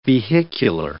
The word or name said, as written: vehicular